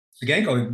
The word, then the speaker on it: Ciganko: Ciganko.